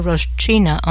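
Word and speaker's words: Roshchyna: There are photos and videos of Victoria Roshchina online.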